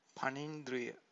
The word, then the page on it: panindriya: Pali